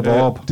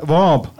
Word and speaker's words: Vorup: Det hedder Vorup. – Det hedder, det udtales Vorup. – Nej. Hvorhen? – På skiltene til byen står der så V-O-R-U-P. – Ja, Vorup.